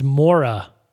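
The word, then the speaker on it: Moira: Last episode, I was talking about X-Men Apocalypse, and I said Morena McTaggart, and I knew what her name was. I just pronounced it wrong. – Right. – It's Mora. Mora McTaggart.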